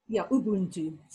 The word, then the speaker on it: Ubuntu: Yeah, it's uBuntu.